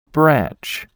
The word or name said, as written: branch